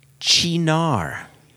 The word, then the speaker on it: Cynar: C Y N A R is pronounced Chinar.